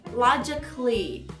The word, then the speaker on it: logically: Na palavra, por exemplo, logicamente, logic, aí tem o A, mas não se fala logically, logically.